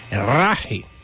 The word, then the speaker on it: Rache: até a pronúncia dessa palavra em alemão. Rache, é assim mesmo.